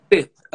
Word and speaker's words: Stith: Sith.